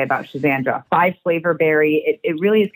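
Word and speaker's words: Schisandra: And so there was one of the adaptogens, Shizandra, am I saying that right? – Shizandra.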